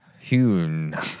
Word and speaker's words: Huynh: Huin? – Hun. Hun. Is it Hun? – Hun. – Huin. – Huin. – Huin. H-U-Y-N-H. – Okay. Brian Huin. – Huin.